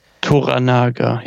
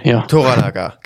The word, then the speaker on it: Toranaga: Wir begleiten aber diesen Lord Toro Naga. – Toranaga, Toranaga, genau. So ist es ausgesprochen, richtig.